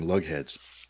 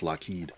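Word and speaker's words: Loughead: And so the Lockheed brothers changed the spelling of their name because they got tired of being called Lugheads. – Yeah, wasn't it Lughheed? – Yeah, phonetically, it was pronounced, you know, some people would pronounce it Lughead, even though it was pronounced Lockheed.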